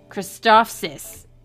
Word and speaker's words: Christophsis: Okay, Goldie said, when he negotiated on Christophis? I can never pronounce that. – Yeah. Fuck that place. Yeah. – How do you say it? I can never pronounce it. – Christophsis. – Thank you.